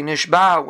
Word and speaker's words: Nishba: That you should make the ayin, you should pronounce the ayin properly. It shouldn't sound like a hey, which would Chas V'Shalom sound like a lashon of captivity. So you say Nishba with an ayin, and this, therefore you have to make a hafsik, that it should mean a lashon of a shvuah.